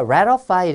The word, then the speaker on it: ratify: ratify, 비준하다, 승인하다, 미국식 발음으로 모음과 모음 사이에 t가 와서 ratify 이렇게 읽지만은 영국 발음으로 그냥 암기를 하세요. ratify 이렇게. 왜냐하면 ratify 할 때 letter, 자, 글자가 파이, 파였어요.